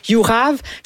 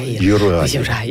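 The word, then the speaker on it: Juraj: Personnellement, moi j'ai de la difficulté avec Yurav Slavkovski. – Yurai.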